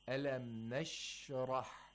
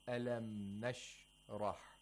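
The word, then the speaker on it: أَلَمْ نَشْرَحْ: Or you should say Alam Nashrah. Not Alam Nashrah. That's incorrect.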